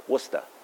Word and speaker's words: Worcester: I guess that's how they say it, Worcester.